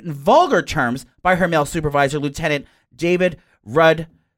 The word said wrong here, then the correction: Rudnitsky: How do you say that? David Rudnitsky.